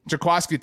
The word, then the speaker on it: Jaquiski: If Jaquiski Tart, pronounce this correctly, right? – Jaquiski, thank you very much.